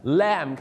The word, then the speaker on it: lamb: ับขาหมูมันก็เป็นข้อใหญ่ของเขาดังนั้นครับคุณก็จะเห็น pork knuckle นะฮะนี่ก็คือตัวอย่างของหมูนั่นเองนะครับหรืออาจจะมี pork belly ก็คือพุงของหมูนั่นนะฮะ belly แปลว่าพุงแต่จริงๆแปลว่าหมู 3 ชั้นนั่นเองครับต่อไปนะครับก็คือ lamb Lamb ครับ L-A-M-B Lamb ไม่ใช่ Lamb นะฮะชาวไทยชอบใส่เสียงไม่โทน Lamb เข้าไปนั่นมันโคมไฟฮะเคี้ยวกันฟันหักสิจ้าพี่น้องต้องออกเสียงว่า Lamb ครับคล้ายๆคุณอ่างเถอเทิงครับ